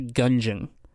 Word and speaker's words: Gungeon: No, Enter the Gungeon.